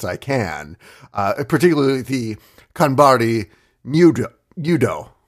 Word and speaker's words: Nyudo: Nyudo, that's how you pronounce it.